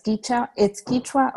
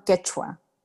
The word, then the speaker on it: Quechua: Yes, it's Quechua or Quechua. It depends on the region where you're from, how you pronounce it.